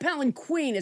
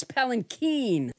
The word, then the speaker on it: palanquin: I think I said palanquin. It's not palanquin. It's palanquin. The accent is on the keen.